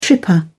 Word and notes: tripper: British English

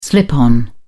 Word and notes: slip-on: British English

